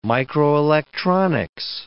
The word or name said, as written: microelectronics